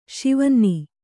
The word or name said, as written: ಶಿವನ್ನಿ